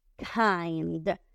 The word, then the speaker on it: kind: siguiente palabra es la palabra kind. Kind. Se escribe K, y de iglesia, N, D. También termina en D, que suene la D fuerte. Kind.